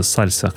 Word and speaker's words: SLSA: это сальса, так и произносится сальса, хотя пишется S-E-S-L-S-A.